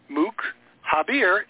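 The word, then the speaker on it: Moukhaiber: Marco Mukaber, spell it right, M-O-U-K, like Mook, you know, like a jerk. Mook Habier, H-A-I-B-E-R.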